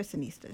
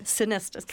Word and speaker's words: Sonesta: At the Clift Royal Sonesta Hotel in San Francisco. And did I say, is it Sonesta or Sonista? – Sonesta, correct.